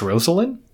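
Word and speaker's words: Rosalynn: Apparently she is, I don't want to say insistent because it's her own name, but like clear that her name is pronounced Rosalind. – Oh, Rosalind. Okay.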